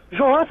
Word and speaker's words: Johan: Johan se escreve que nem o Johan Cruyff. J-O-H-A-N. – Você tá brincando. – É sério. – Sério? – Peraí, então é Johan, não é Johan? – Johan.